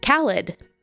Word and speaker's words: Khalid: Khalid.